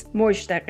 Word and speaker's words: Mozhdeh: Yeah, it's Mozhdeh Gheini.